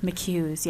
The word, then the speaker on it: McHugh's: McHugh's. Is that how you pronounce it? – McHugh's, yes.